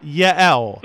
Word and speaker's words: Yael: Oh, it's Yael. – Yael. – Yeah. – Okay. – Two syllables.